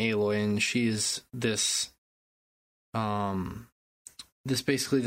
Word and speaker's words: Aloy: Is it Aloy or Alloy? – It's Aloy. – Aloy, I thought so.